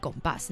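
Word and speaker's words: compás: 对对对，这是第一次、嗯、那这个其实我们的节目叫做爵士弗朗明哥的华丽冒险、嗯、其实我们还有一个外文的标题名称叫做 Gombas，那 Gombas 的拼法就是用英文来念的话就是 C-O-M-P-A-S，但是因为西班牙有重音系统，所以在这个 A 上面有个小小的重音，所以它念成 Gombas。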